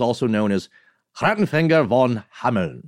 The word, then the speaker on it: Hameln: In German, I'm going to do my best here, is Hameln, with a soft N. – Pretty good. – I'll just see how many emails come rolling in from our dear German listeners. Yeah, but that's the pronunciation in German.